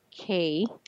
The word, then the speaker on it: Kapha: but that's pronounced K or spelled K-A-P-H-A, right? – Yeah. So I'm pronouncing a unaspirated K, which is K. – Okay. – So for Americans, they hear it as a G, but it's actually a K, but I'm not aspirating. So it's not cat, okay.